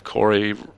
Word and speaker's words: Corey: I believe Corey rhymes with story and allegory and things like that.